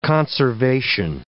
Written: conservation

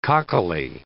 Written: cockily

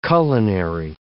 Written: culinary

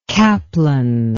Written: Kaplan

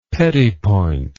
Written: petit point

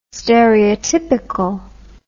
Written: stereotypical

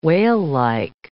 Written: whalelike

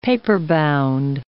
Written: paperbound

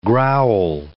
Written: growl

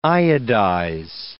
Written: iodize

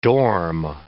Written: dorm

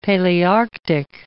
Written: Palearctic

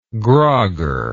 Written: grager

